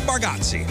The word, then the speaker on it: Bargatze: We've got tickets to go see Nate Bargatze. Am I saying that right? Is it Bargatze? – Nate Bargatze. – Bargatze.